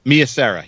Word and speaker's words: Mia Sara: Mia Sierra, how do you say her name? – Sorvino? – No, S-A-R-A, Sierra? – Mia Sara.